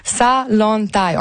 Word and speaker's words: Zalantay: Salantay. – Je vais noter ça comme il faut. – Dites-nous-le comme il faut. Oui, Salantay. On prononce pas le Z.